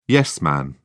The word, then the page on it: yes-man: English; British English